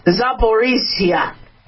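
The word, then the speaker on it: Zaporizhzhia: Zaporizhia.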